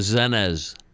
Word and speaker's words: Zennez: Gary Lucas and Peter Willems on the Zennez label out of Holland. How do you spell that? That's W O oh, Z E N N E Z. Zennez. Zennez. It's Zen Zen.